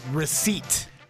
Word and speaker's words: receipt: For receipt. – Receipt. – Oh, yeah. – Explain to me why there's a P in that. – What? – Why is that P there? – That's silent P? – Who invited that P to the party?